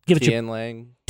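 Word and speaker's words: Tianlang: Is it Twang Lang? Twang Lang? – I think. Tian Lang.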